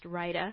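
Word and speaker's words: Rayda: It's R-A-Y-D-A, but it's pronounced Rida.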